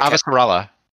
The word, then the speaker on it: Avasarala: Avasarala.